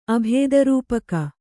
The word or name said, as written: ಅಭೇದರೂಪಕ